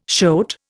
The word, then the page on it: shoat: Indian